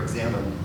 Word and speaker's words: Examen: It was something that St. Ignatius did called the Prayer of Examine. It's spelled funny, but you can look at St. Ignatius, the Prayer of E-X-A-M-E-N, maybe.